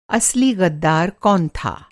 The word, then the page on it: ग़द्दार: masculine noun